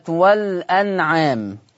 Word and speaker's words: الأنعام: عندنا في الآية 14 عندنا أولا كلمة والأنعام، طبعا معروفة لدى الجميع إن فيها هذا اللفظ فيه النقل، ولما هنقل حركة الهمزة إلى اللام فتصير اللام مفتوحة فتنطق هكذا والأنعام، فتنطق والأنعام.